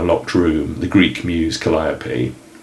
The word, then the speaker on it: Calliope: So the canonised version is Calliope.